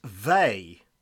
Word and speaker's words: Veil: Simone Veil's last name is French and therefore pronounced Veil, rhymes oh. with Bay.